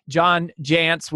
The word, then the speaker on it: Jantsch: John Jantsch, which is pronounced like pants, but spelled a little bit different.